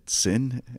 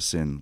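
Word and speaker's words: Sinn: I always pronounce it Sin, and I feel like I should probably pronounce it the German way, but I'm just going to say Sin.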